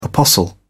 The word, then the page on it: apostle: British English